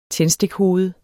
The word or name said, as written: tændstikhoved